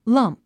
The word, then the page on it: lump: lʌ́mp; ラァンプ